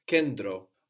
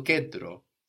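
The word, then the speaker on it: kendro (κέντρο): You can hear it like endi or like di. To kendro, to kedro. So this is spelled K-E-N, which looks like a V, no. T, it's spelled with the T, R, O. So N-T together inside of a word is going to give us a D or an N-D, not a T.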